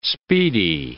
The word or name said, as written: speedy